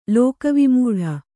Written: ಲೋಕವಿಮೂಢ

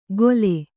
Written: ಗೊಲೆ

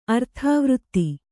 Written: ಅರ್ಥವೃತ್ತಿ

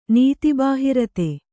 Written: ನೀತಿಬಾಹಿರತೆ

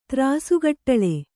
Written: ತ್ರಾಸುಗಟ್ಟಳೆ

